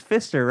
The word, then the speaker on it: Pfister: It was like the faucet box downstairs I was telling you guys about that said it was P-F-I-S-T-E-R. Yeah. Yeah, and I was like, how do you guys pronounce this? And my mom goes, honey, it's Pfister, right?